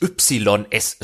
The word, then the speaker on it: Ys: Das sind diese Spielereihe, die sich eigentlich YS schreibt, aber von den Fans und Kennern, wie wir hier ja unter uns so welche sind, Ys ausgesprochen werden.